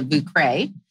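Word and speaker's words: Vieux Carré: and it's called the Vucre.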